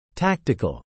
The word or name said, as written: tactical